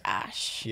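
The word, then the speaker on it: X Æ A-12: So his, the mother Grimes has said it's pronounced Ash.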